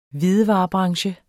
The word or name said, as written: hvidevarebranche